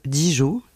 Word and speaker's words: DJO: DJO, je pense que ça se prononce Dijo, j'essaye, sur RTS Première, c'est le dernier choix de Bastien Arnold avec ce titre End of Beginning.